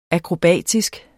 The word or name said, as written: akrobatisk